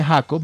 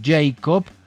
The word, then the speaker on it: Jacob: Jacob, no se dice Jacob, Mayro, sino Jacob.